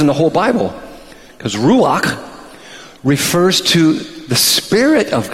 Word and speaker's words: ruach: It's the word ruach. Now, in order to say it right, you have to spit at the end.